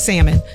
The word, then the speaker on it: salmon: the L is silent. It's salmon.